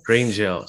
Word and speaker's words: Grange Hill: I'm not from the UK, but apparently it's a show that's been going on for like 30 years, and it's called Grunge Hill, if I'm pronouncing it right. – It's called Grange Hill. – Grange Hill, sorry.